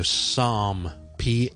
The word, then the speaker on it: Psalm: OK，咁亦都有一個 silent P 嘅 psychology 啦，right？psychology 啦，心理學啦，psy c h o l o g y，同埋我哋聖經其中一本書叫 Psalm，p s a l m，p s a l m，咁啊詩篇，咁啊亦都係咧來自古希臘文，以前啊好耐之前啦，咁個 P 音亦都係唔讀嘅，咁就唔好讀成 P 三咁啦。